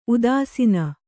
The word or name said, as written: ಉದಾಸೀನ